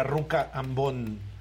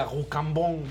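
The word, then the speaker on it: Rue Cambon: la gente que no sabe hablar francés no lo dice bien, yo sí lo digo bien. Antes las pasarelas eran en la ruca ambón.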